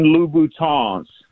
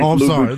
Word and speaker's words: Louboutin: And then most importantly, so the women that listen to the show don't start tagging you on YouTube, they are Christian Louboutins. You got to pronounce it with the French Louboutin.